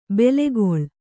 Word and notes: ಬೇಲಿಕಳ್ಳಿ: Kannada

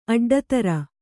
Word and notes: ಅಡ್ಡಿತರು: kannada

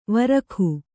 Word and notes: ವೃಕ್ಕೆ: vřkke

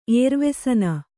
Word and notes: ಏರ್ವೆಸನ: kannada

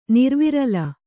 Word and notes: ನಿರ್ವಿರಲ: nirvirala

